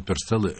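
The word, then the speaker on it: Purcell: Так это был Генри Пёрселл, так как его произносят в Англии, и мы послушали с вами фрагмент из полуоперы «Король Артур».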